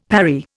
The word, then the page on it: parry: Indian